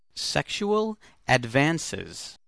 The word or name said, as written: sexual advances